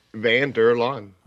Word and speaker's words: Van der Laan: Van der Laan.